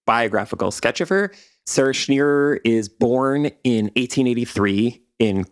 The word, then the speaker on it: Schenirer: And her last name, it's like Schenirer, but you kind of just roll the two R's together.